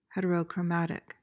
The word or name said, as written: heterochromatic